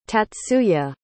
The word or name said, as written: tatsuya